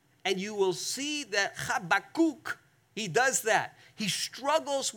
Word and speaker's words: Habakkuk: We always say Habakkuk in church. You pronounce it in Hebrew, Habakkuk. That's how you pronounce it. Try it with me. Habakkuk.